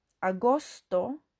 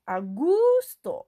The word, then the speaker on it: agosto: Many students try to say agusto, but in Spanish, August has no letter U.